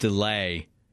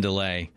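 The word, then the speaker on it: Dele: Bison's last name is pronounced Delay. Bison Delay.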